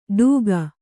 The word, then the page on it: ಡೂಗ: kannada